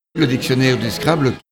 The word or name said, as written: Dictionnaire de Scrabble